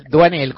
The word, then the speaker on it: Doinel: Los 400 golpes es una película de François Truffaut, 1959, es el primero de la saga de Antoine ¿cómo se dice Doinel? Duanel, como guapo, Nelly, y sale bien, Duanel.